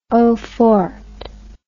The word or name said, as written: eau forte